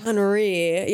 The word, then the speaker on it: Henri: And these flyers are signed with the name Henry Dagousset. – Is it Henri? – Henri. Yeah, I wanted to say it like that, but I didn't. Henri. Now I can.